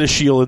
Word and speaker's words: Achille: They pronounce it Achille in the books because he was French, but it's spelled like Achilles, right?